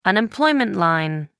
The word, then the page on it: unemployment line: American English